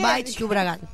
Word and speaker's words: Baix Llobregat: Estamos aquí en el Baix de Llobregat. A ver si pronuncio bien. – Baix Llobregat. Sí, muy bien, amiga, vas avanzando.